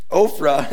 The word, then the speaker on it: Ophrah: No. Ophrah is the correct pronunciation.